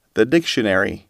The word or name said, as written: dictionary